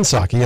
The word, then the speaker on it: Psaki: And I was thinking, I was trying to get my arms around how you pronounce her name, and it just doesn't sound like a name. It sounds more like a word. It's Jen Psaki, and I, of course, I love Psaki.